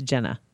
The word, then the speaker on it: Gena: One of the people that was in the movie is, and I always called her Gina Rollins, but I guess her name rhymes with Henna. So it's Jenna, G-E-N-A, but it's, her name is Virginia.